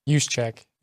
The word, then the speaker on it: Juszczyk: Kyle Juszczyk. – Juszczyk.